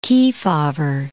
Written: Kefauver

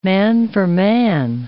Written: man-for-man

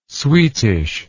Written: sweetish

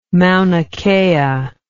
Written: mauna kea

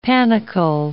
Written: panicle

